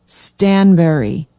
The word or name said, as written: stanberry